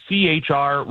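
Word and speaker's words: C-HR: Plus, you've been spending some time in Toyota's new, is it a CHR? Is that how you pronounce that? – Yeah. – Okay. – Yeah, that's correct. It's their new CHR, which is technically the second generation of this vehicle.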